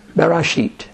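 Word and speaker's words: Barashit (בראשית): Barashit. – You were mispronouncing that on purpose. – No, that's the way it's pronounced.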